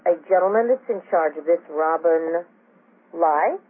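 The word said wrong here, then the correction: Li: Robin Lee. – Lee, excuse me, I mispronounced that.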